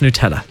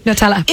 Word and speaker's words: Nutella: N-U-T-E-L-L-A. – Nutella. – If you're in the UK, they pronounce it Nutella. – In the Nutella. – UK. – The U is sharp. Nutella. Well, that makes sense because there's nut in it.